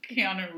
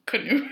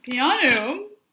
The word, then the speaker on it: Keanu: My mom can't pronounce Keanu Reeves. – Who can, frankly? – Without a doubt, she calls him Canoe Reeves. – Canoe? Mm-hmm. It's not that far off.